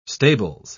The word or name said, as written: stables